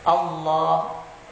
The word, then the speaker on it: Allah: Dalam pengucapannya ini lam mufakham. Allah.